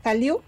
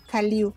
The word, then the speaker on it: Hallyu: De hecho, tiene su palabra, es eh, la Hallyu, que significa la ola coreana. – ¿Hallyu? – Hallyu. – ¿Como el niño ese peloncito? ah, cierto, se pronuncia distinto.